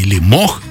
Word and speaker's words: Лемох: Я говорю сейчас о сегодняшнем имениннике, о Сергее Лемахе. Сергей Лемох, как правильно говорить,